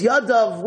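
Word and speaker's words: Yadav (יָדָיו): The way it's written is Yadai. It's missing a Yud. The way it's pronounced, it's Yadav with a Yud.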